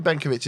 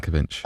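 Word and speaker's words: Benković: Probably Benkovic is the right way of pronouncing it. Is Benkovic? Yeah, I think definitely yeah. Benkovic.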